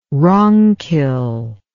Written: ronquil

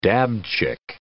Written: dabchick

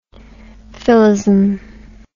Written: philism

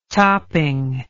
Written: topping

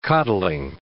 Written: coddling

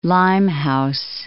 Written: Limehouse

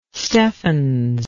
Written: steffens

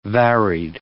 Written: varied

